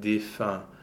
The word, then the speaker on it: défunt: Another pronunciation point, we don't often get the O-E nasal, but here we actually have it in a rhyme in the first stanza. – Yes, parfum and défunt.